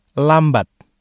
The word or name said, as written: lambat